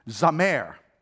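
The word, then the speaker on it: zamar: The word for today is pronounced zamer, and it is translated as a command.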